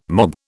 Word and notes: mob: British English

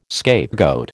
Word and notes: scapegoat: American English

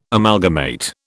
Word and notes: Amalgamate: British English

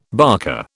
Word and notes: barker: British English

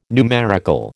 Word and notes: numerical: American English

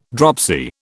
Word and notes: dropsy: British English

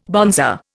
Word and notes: bonzer: Indian

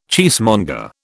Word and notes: cheesemonger: British English

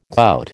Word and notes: gout: American English

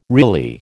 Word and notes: really: American English

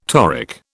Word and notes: toric: British English

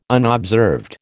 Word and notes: unobserved: American English

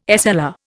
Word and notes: SLR: Indian

